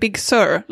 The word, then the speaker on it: Big Sur: it sounds like Big Sur, like S-U-S-S-I-R. – That's how you say it.